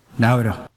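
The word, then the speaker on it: Naoero: Nové jméno tedy není Nauru, ale píše se to Nauero. Prezident Adeang to vyslovuje něco mezi Nauru a Nauero. To O na konci možná dokonce jako by skoro bylo třeba přehláskované, něco jako v němčině.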